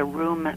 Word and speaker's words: RHUME: And through that, I developed this five-step method called the RUM method. – Mm-hmm. – It's an acronym. It's spelled R-H-U-M-E.